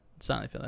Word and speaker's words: Steinfeld: Steinfeld, that's how you pronounce it.